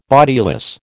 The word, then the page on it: bodiless: American English